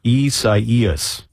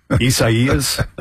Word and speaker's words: Isaias: It's four syllables, apparently. I do believe that we should have a congressional a bill to pass that says any hurricane that we name should be easily pronounced. I mean, Isaias. I've honestly never heard of that name before. It's apparently Portuguese.